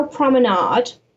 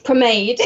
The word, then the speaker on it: Pomade: So in my bargain bag, we have the Revolution Pro Brow Promenade. – Pomade.